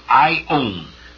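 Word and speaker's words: ion (αἰών): The Greek word is aion in the Greek, ion. It's a long O.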